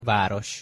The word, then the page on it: varos: Hungarian